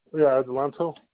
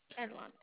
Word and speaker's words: Adelanto: And also, just to verify, that's the 14591 Materi Place in, I'm not sure how you say it, Adelanto? – Adelanto. – Adelanto.